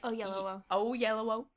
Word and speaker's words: Oyelowo: David Oyelowo. Did I say that right? – Oyelowo.